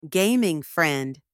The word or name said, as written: gaming friend